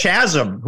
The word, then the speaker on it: chasm: He wants chasm. Who uses chasm in their everyday language? – But anyway, chasm or chasm? – It's hard C sound there. – Chasm.